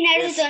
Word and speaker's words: Naruto: Luego tenemos a nuestro crack, nueve años, un gran fan del Naruto. No sé si lo he dicho bien, luego nos lo explicará. – Sí, Naruto, Naruto, es Naruto.